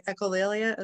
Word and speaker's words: Echolalia: Echolalia, is that how you? – Yes.